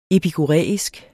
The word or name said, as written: epikuræisk